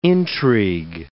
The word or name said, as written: intrigue